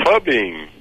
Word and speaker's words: Fubin: Eu não sei pronunciar, não. É Fubin. Como que é? – Fubin. – Ah, então é isso mesmo.